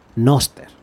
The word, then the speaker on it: Nostr: Ese algo es Noster. Se escribe N-O-S-R-T.